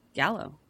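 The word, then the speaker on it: giallo: The word gallo is Italian for yellow. Pronunciation. Gallo.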